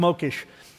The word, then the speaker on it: Mokhes: Then there's a group called the Mokesh. M-O-K-H-E-S. It's pronounced Mokesh.